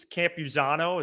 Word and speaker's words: Campusano: It's Campusano.